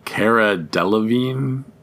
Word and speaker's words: Cara Delevingne: Cara Delevingne, but that's not how it looks.